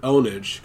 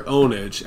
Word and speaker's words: Pwnage: Apparently, it's actually pronounced Pure Onage because the P is like you mistyped it with an O.